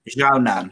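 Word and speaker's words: Xiaonan: Okay, so first fight on the main card was Claudia Gadelha versus Yan Xiaonyan. I think that's how you say her name. – I think it's Zhao Nan.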